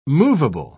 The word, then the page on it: moveable: British English